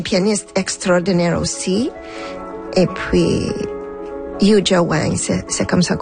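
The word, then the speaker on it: Yuja Wang: Yu Jia Wang.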